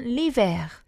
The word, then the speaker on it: l'hiver: 겨울은 l'hiver. – L'hiver. – 아쉬는 발음이 안 되죠? – 네.